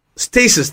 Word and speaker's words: stasis: status. Is that the right way to say it? – Stasis.